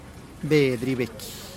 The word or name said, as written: bedih geiik